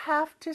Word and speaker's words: have to: You have to sleep. 너 지금 자야 돼. 자야만 해라는 의미입니다. 이것도 같이 한번 연습해 볼 텐데 아까 have 발음이 V가 아니라 F로 변한다고 했죠.